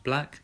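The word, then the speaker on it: 6LACK: You know, that's what that style lies, that's 6 lakh, pronounced Black.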